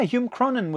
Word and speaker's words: Hume Cronyn: Yeah, Hume Cronyn was the guy that adapted the screenplay.